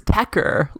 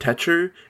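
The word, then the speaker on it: Techer: and he pronounces it Tetcher, and I hate that. I despise that.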